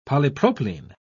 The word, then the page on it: polypropylene: British English